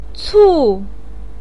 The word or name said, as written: cu4